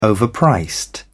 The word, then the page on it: overpriced: British English